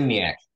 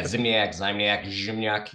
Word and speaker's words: Zimniak: Zimniak. – Zimniak. I totally forgot. – Depends how Polish you are. But yeah, Zimniak, if you're my grandma.